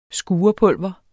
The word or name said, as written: skurepulver